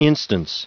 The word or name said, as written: instance